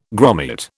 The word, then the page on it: grommet: British English